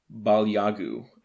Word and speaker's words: Balyagu: Balyagu, I believe, is how that's pronounced. B-A-L-Y-A-G-U.